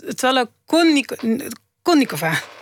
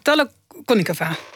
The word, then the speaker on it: Tolokonnikova: Nee, nee, een onbeklemtoonde O is een A. En alleen de derde O is beklemtoond. Dus het is Tala... – Tolokonikova. – Tolokonikova.